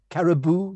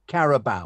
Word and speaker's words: Carabao: Anyway, the other thing is pronunciation of Carabao. Caribou, you always say? Carabao. – Yeah, but I call it Caribou because it's after a Pixies song called Caribou.